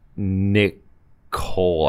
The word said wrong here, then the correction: Nicholl: I'm, I'm, sorry, I butchered that name. It's Colin R. Nicholl, I guess. It's two L's, one one O.